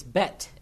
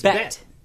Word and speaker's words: Bette: Am I not saying it right then? – It's Bette.